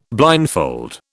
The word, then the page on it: blindfold: British English